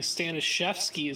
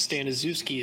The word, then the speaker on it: Stanazewski: Stanazewski is the Polish way, correct way to say my name, whereas Stanazewski is the English version.